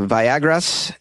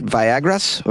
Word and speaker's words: Viagras: But rival cartels like the Viagras and the Jalisco cartel have moved in. Did I say that right? Viagras? Or is it Viagras?